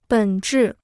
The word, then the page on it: 本质: běn zhì